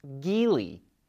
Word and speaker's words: Geely: LEVC is owned by Chinese automaker Geely, or which we recently found out is pronounced Geely.